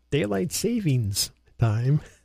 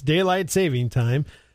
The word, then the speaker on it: daylight saving time: Most people go, daylight savings time. There's no S in savings. It's daylight saving time is Sunday.